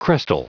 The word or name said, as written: crestal